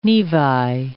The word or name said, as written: nevi